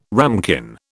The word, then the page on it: ramekin: British English